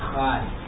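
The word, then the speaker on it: chai (חי): By the way, chai, you're saying chai a lot. The Hebrew pronunciation is chai.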